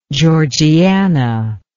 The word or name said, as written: georgiana